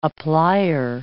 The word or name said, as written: applier